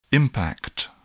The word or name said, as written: impact